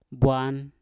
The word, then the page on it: bwan: Mpur